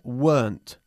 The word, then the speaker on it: weren't: Recuerda que la contracción de were not se pronuncia weren't, una sola sílaba.